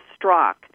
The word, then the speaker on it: Straka: This was a gentleman, Brandon Strock, who I've learned that he pronounces, even though it ends with an A, Straka, he pronounces Strock.